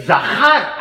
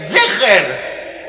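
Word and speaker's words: Zeher (זכר): Y David le dice, no, estás pronunciando mal. No dice Timhe et Zahar, dice Timhe et Zeher. El recuerdo de Amalek, Zahar es hombre, y Zeher es el recuerdo, había que matarlos a todos.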